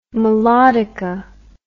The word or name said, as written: melodica